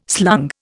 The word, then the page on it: slung: Indian